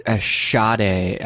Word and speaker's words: Sade: Oh, Sade, I believe is the pronunciation.